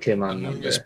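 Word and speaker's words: Amoonguss: Amoongus.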